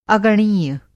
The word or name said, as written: अगणनीय